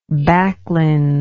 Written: backlins

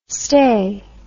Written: stey

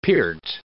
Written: peart

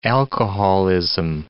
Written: alcoholism

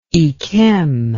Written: yquem

